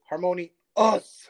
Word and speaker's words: Harmonious: Uh, I'm I'm harmonious. Like I, I, I don't like when people like use. – I, I think you pronounced it wrong. It's harmony us.